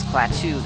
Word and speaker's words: Klaatu: My name is Klaatu. That is spelled K-L-A-A-T-U.